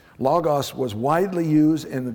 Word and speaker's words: logos: So, the term word that is used here is a common Greek word known as logos, not logos.